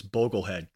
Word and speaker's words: Boglehead: And that was a specific question, was talking about the moment you became, and I've practiced pronouncing this correctly, it's Boglehead, correct? – Correct, yeah. Boglehead.